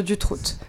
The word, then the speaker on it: Dutroux: Bien prononcer le T à la fin de Dutroux.